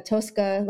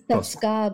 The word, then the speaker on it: Toska: Well, it's, I think like in English, it would just be Tosca. Like in Russian, it's Tosca, but like, you know, we don't need to be pretentious about it. – Tosca, sorry, Chris, I keep knocking over coins.